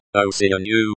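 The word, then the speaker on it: Осенью: The word for autumn is pronounced Osinyu with a soft y sound at the end.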